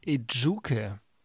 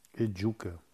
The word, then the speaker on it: Ejuke: Ejuke. Ejuke. Also äh, im, im Vornamen hätte mir noch ein, ein, ein, sagen wir mal, ein Betonungszeichen gut getan. Chidera Ejuke, würde ich mal sagen.